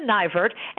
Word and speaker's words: Neivert: It's Neivert. Nye like pie. – Oh, sorry about that. Edna Neivert, and it is 2:25, February 25th, and it is 11:48 a.m.